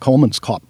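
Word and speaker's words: Kolmanskop: We're going to be shooting, in fact, one place we went, Kolmenskop, I believe is how it's pronounced, it's German,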